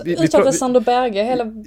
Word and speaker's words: Berge: norskt och det uttalas Berge. Det är bara att, att han av någon anledning försöker få någon slags internationell touch på det.